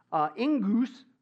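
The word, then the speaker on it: ingus (ἐγγύς): Now, you combine that with the word near, ingus, okay, is how you pronounce it there.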